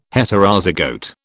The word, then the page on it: Heterozygote: British English